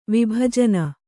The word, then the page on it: ವಿಭಜನೆ: Kannada